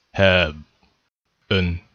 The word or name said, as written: have an